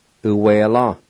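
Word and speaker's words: uwela: Number two, liver, u-we-la.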